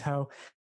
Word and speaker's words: Ho: Ho is actually a Vietnamese last name, but apparently, when my grandparents were coming over and they were fleeing from the communists, um, it's actually mis- pronunciation, a misspelling. And so it's actually supposed to be H E.